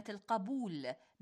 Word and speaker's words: القبول: إخوتنا أخواتنا المستمعين والمستمعات، كثيراً ما يقع الخطأ في تلفظ كلمة جنوب التي ينبغي أن تلفظ بفتح الجيم لا بضمها، ونفس الخطأ الذي يقع في كلمة الجنوب وهو ضم الجيم يقع في كلمة القبول بفتح القاف وهو الصحيح.